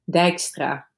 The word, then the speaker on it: Dijkstra: Dijkstra. That's also a really Dutch name.